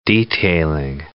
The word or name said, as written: detailing